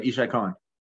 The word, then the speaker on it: Yishai Cohen: And now I kind of want to talk about your paper that you've written alongside Yishai Cohen. I hope I'm pronouncing that. – Yeah, that's right. Yishai Cohen.